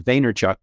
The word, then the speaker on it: Vaynerchuk: Well, they call him Gary V because Gary V is like the entrepreneur's entrepreneur, but I think the pronunciation of his last name is Vaynerchuk, but you call him whatever you want.